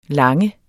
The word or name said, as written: lange